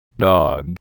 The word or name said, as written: dog